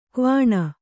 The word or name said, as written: ಕ್ವಣ